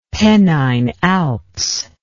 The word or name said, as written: Pennine Alps